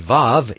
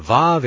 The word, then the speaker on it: Vav: And according to our knowledge, the Vav is pronounced as a V by Sephardic Jews. The Vav